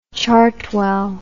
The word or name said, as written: chartwell